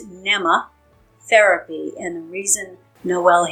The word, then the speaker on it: Mneme: It's spelled M-N-E-M-E. So first thing, tell us how you actually pronounce this. – You pronounce it like a little girl's name, Emma, with a N in front of it. So it's Nema. Therapy is phonetically how you want to say it.